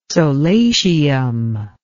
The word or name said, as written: solatium